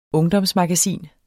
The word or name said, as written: ungdomsmagasin